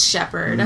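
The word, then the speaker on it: Sheppard: And people loved my last name because it's Shepherd. And even though it's spelled like P-P-A-R-D, it's not like a herder of sheeps.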